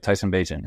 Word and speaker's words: Bagent: Tyson Bagent, right? – Perfect. Yeah, you're one of the only people to say it right.